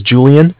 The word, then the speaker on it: Julian: My name is Julian, and it's spelled J-U-L-I-A-N, and I'm calling from Bank of Cardiff. It's C-A-R, D as in dog, I-F-F, as in Frank.